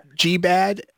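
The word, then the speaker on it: GBD: is it pronounced G-bad or a G-B-A-D? – So, what was the word? I didn't hear that. – G-bad, golden brown and delicious. – Oh, I'd say G-bad, yes.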